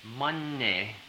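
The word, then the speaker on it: Mani: pronunciation is Tibetan in this case, even though the spelling would be spelled as if you were pronouncing it in the Sanskrit. So it's Om Manne, Manne with the back of the nose closed, Manne, it's against the roof of the mouth, Manne, Manne, Pedme, Pedme, Pedme, Hung, as if it were spelled H-N-U-N-G, Hung, Hung.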